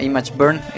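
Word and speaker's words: ImgBurn: Y bueno, pues yo les voy a recomendar un programa muy sencillo, pero muy poderoso, que se llama Image Burn. De hecho se escribe IMG Burn.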